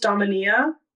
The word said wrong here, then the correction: Damiana: Damania? I don't know. It's another... – Damiana? – Damiana. Thanks, Queen.